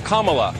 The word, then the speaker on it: Kamala: Her name is pronounced comma like the punctuation mark. Look, Kamala.